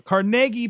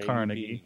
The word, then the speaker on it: Carnegie: It has a trouble pronouncing, but Carnegie, PA, not Carnegie.